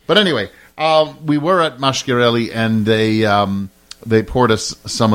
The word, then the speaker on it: Masciarelli: If a word has a C in it, it is pronounced with a CH, as in Masciarelli. But if it has a CH in it, it is pronounced like a K.